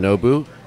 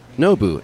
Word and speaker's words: Nobu: My girlfriend jokes that my name is not doesn't just it's not just Nobu, it's Nobu, N-O-B-U, Nobu.